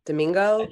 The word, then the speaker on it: Domingue: yeah, and especially because their backup goalie, uh, Louis Doming- Dominguez, Domingue, Domingo, how do you say it? Doming? – Doming. Yeah.